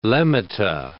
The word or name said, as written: lemmata